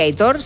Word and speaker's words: Aitor: que dice, por favor, que Aitor se pronuncia como se lee. – Sí, no. Aitor. – La acentuación es en la O. – En la O. – Claro. – Aitor.